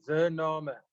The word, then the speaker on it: Zoernamer: The easiest is just surname with a Z, Zoernamer.